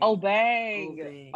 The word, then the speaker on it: Obeng: So, can you please tell me how to correctly pronounce this man's name? – So Dr. Michael Obeng. – Obeng.